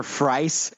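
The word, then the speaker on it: Fries: Like, instead of Victor Freeze, they kept calling him Victor Freese. And they're like, it's Freese. It's pronounced Freese.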